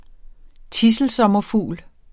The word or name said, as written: tidselsommerfugl